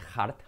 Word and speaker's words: heart: La letra semiclave H, porque es fácil, pero fijaos que no se dice heart, sino heart. ¿Cómo es limpiando las gafas? Heart. La letra clave R, heart.